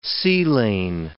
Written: sea-lane

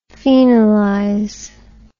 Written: phenolize

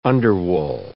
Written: underwool

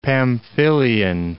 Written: Pamphylian

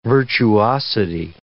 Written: virtuosity

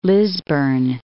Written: Lisburne, Cape

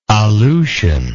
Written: aleutian

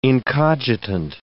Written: incogitant

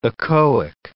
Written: echoic

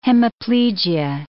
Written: hemiplegia

